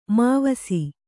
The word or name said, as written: ಮೈವಸ